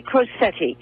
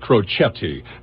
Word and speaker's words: Crocetti: I asked an Italian speaker, and he said it's pronounced Crocetti, but I was wondering if it was Anglo. So we'll call it Crocetti, okay?